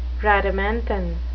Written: rhadamanthine

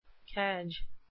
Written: cadge

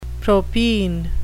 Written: propine

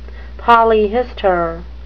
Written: polyhistor

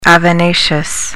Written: avenaceous

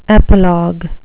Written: epilogue

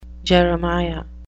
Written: Jeremiah

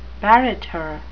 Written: barrator

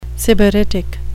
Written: sybaritic

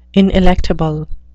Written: ineluctable

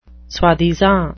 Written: soi-disant

